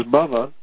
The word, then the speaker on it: bhava: Because bhava, which just means existence, with a short A, is frequently used to mean material existence, not just existence as such.